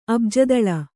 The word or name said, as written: ಅಬ್ಜದಲ